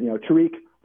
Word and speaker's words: Tariq: You know, he said somebody asked him how to pronounce his name, whether it was, Tarek or Tariq. And he said, Tariq or Big Daddy.